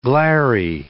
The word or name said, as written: glary